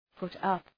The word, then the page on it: put-up: ‘pʋt,ʌp